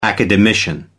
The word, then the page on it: academician: American English